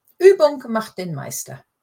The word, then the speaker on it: Übung macht den Meister: Phrase 1, Übung macht den Meister. – Yeah, Übung macht den Meister.